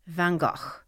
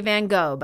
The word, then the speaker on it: Van Gogh: Of course, my last but not least is one of the most iconic artists that sparks fascination in just about everyone, Vincent Van Gogh. Now, only Americans say Van Gogh, by the way. The correct pronunciation is Van Gogh. And even in England, they'll say Van Gogh.